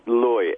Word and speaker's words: Leu: Okay, it's Leu, L-E-U. It's the same E-U as Freud or Deutsch. It's the German way of pronouncing okay.